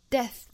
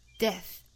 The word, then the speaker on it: death: Death 尾部的 th 要读成一个清辅音的送气的 death, death.